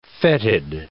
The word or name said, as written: fetid